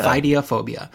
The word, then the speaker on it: Ophidiophobia: Ophidiophobia. I think that's how you say it. – Yeah. – Ophidiophobia.